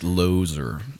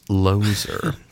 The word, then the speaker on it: Lozier: It's pronounced Lozer. – It's Lozer. Sorry. – Sorry. – Is it really? – No, I just... Oh. It looks like loser.